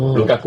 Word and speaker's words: ลูกากู: ตู้เย็นคือใครลูกากูไงลูกาก